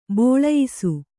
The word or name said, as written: ಬೋಳಯಿಸು